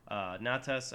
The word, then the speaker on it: Natas: Your second character goes by the name Natas. – Natas. Can you spell that for me? – Sure. N-A-T-A-S.